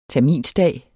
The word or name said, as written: terminsdag